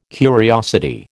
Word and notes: curiosity: American English

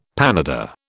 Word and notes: panada: British English

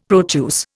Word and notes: Proteus: Indian

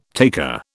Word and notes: taker: British English

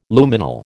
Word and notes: Luminal: American English

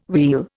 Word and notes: wheel: Indian

